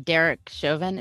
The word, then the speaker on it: Chauvin: And if you're not aware, the Derek Chauvin, is that how you say his name? – Yeah.